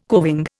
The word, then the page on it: coving: Indian